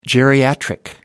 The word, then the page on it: geriatric: American English